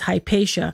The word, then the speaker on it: Hypatia: There was a very famous female philosopher in Alexandria called Hypatia. In English, they pronounce her as Hypatia.